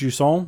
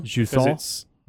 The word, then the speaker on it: Jusant: I think it might be pronounced Jusant. – Jusant. – Because it's Don't Nod.